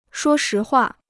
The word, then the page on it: 说实话: shuō shí huà